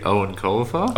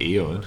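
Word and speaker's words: Eoin Colfer: Did you ever read Owen Colfer? – Oh, I love Owen Colfer. Artemis Fowl, worst bloke. Best books. – Dude, I mispronounced like two of those four words you just said. – Yeah. – Like as a kid just reading it, I was like, Eon.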